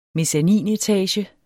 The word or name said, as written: mezzaninetage